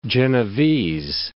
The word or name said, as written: Genevese